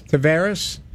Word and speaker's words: Tavares: Tavares.